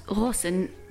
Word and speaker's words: Ghosn: Carlos Ghosn, which is how his name is actually pronounced in Arabic, was born in Brazil to Lebanese parents.